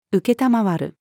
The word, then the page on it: 承る: female